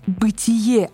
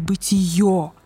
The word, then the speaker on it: бытие: Да, и, кстати, если вы еще не знаете, правильно говорить «бытие», а не «бытие», Каждый раз, когда вы неверно произносите это слово, где-то в России тихонько плачет один философ.